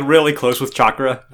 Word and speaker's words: chakra: Naruto mispronounces chakra. He calls it chatara, which Konohamaru imagines a funny ninja kitty cat and asks if he means chakra.